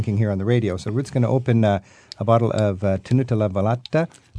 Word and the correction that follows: Tenuta Le Velette: Am I pronouncing that correctly, Cecilia? – Tenuta Le Velette.